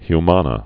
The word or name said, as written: vox humanas